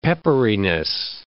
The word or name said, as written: pepperiness